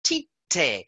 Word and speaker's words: Tite: Andrew from Philadelphia has kindly sent us a message saying that the way to pronounce the coach's name is actually Tite. So Tite as in cup of tea and Te as in television, Tite, which is obviously very close to titty.